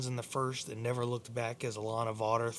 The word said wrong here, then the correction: Elena Vawter: threw a, th- or Elena Vawter, excuse me. Apparently, I'd been pronouncing her name wrong the entire time, the entire season.